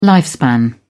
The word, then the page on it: lifespan: British English